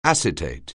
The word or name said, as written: acetate